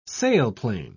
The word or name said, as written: sailplane